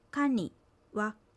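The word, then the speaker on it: カニ: かに、かには金属という意味です。アクセントはかにあります。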